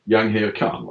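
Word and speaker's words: Jahangir Khan: Yanghir Khan, va? – Det stämmer, ja. Det var bra uttal.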